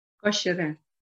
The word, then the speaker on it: Cocheret: Cocheret.